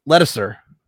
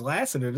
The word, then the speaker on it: Lasseter: John Lasseter, who was the director. – Oh, is that how you say his name? I thought it was Lasseter this whole time.